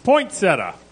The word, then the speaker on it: poinsettia: Poinsettia. – Absolutely correct. Even though you said poinsettia instead of poinsettia. – Is there another syllable?